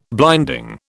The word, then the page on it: blinding: British English